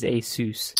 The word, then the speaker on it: Asus: But I don't doubt the right way to pronounce it is Asus.